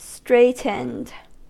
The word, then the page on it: straightened: American English